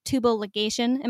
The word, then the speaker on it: tubal ligation: tubal ligation. Am I saying that right? – Yes.